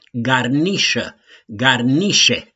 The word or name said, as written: GARNIȘĂ